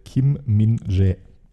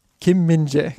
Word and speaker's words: Kim Min-jae: Der heißt Kim Min-jae. – Kim Min-jae, genau. Ja, du hast nochmal auf YouTube, nur falls jetzt jemand mit der Stirn runzelt, auf YouTube nochmal nachgeschaut, wie wird er denn ausgesprochen?